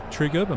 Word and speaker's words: Trigub: And I'm very pleased to welcome my guest for this segment, Dan Trigub.